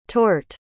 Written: torhte

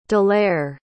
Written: delaire

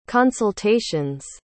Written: consultation's

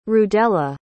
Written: rudella

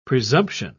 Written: presumption

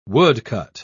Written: woodcut

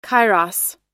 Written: kairos

